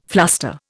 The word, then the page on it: fluster: Indian